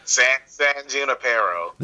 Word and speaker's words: San Junipero: San Junipero.